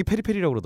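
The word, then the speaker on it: Peri Peri: 제가 계속 페리퍼리라고 읽었는데요. 음. 일동형이 페리페리라고 그러더라고요. 예, 페리페리가 아. 맞는 거예요.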